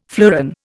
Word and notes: fleuron: Indian

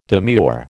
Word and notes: demure: American English

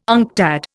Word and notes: UNCTAD: Indian

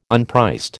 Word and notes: unpriced: American English

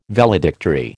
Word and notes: Valedictory: American English

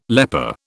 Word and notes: leper: British English

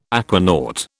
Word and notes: aquanaut: British English

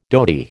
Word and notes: dhoti: American English

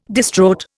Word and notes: distraught: Indian

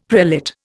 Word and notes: prelate: Indian

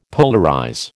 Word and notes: polarize: American English

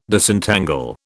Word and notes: Disentangle: British English

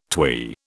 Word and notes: twee: British English

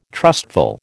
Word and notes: trustful: American English

